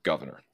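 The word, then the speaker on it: Gouverneur: His first name was just Governor. It is spelled a little bit differently, but Governor Morris. I've heard it pronounced a couple of different ways, but Governor is the one that I like best.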